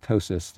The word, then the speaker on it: ptosis: When somebody has this sinking called ptosis, this funny P word, where the P is silent, I see it missed all the time.